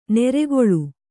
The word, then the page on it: ನೆರೆಗೊಳು: neregoḷu